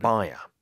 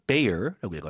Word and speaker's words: Bayer: 자, 이 베이어라고 읽는데 미국 발음으로는 영 발음에서 어떻게 읽나요? 이 회사를? – 바이어. – 바이어. – 바이어. – 우리는 또 한국어에서는 바이엘로 표기를 해요. – 오, 네. 그래서 이게 좀 흔히 좀 회사 이 스펠링하고 약간 좀 다르게 있습니다. 어쨌든 미국 발음은 Bayer라고 읽어요.